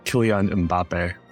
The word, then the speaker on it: Kylian Mbappe: So Kylian Mbappe, is that right? – Kylian Mbappe. – Yep. Kylian Mbappe was at number three.